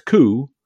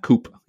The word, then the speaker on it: coup: And it's coup, not coop, even though it's spelled C-O-U-P.